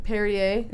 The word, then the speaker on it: Perrier: Perrier.